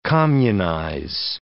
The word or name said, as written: communize